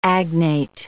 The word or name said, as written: agnate